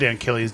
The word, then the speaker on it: Antilles: He did slightly mispronounce it, as of course, as he does. As it's Bale and Tilly's. – Oh, right. – Okay. I said Antilles, didn't I? You said Bales and Tilly.